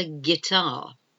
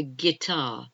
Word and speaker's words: guitar: G-U-I-D-E. A guide. And something I'm sure you're familiar with is a guitar. A guitar. Again, the silent U. G-U-I-D-E. T-A-R, guitar. So those are some of the silent letters using the word, the letter U.